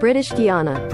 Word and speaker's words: British Guiana: That's British Guiana. That's how you pronounce it, right? – British Guiana. – British Guiana. I got it right.